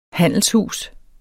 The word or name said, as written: handelshus